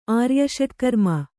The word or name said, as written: ಆರ್ಯಷಟ್ಕರ್ಮ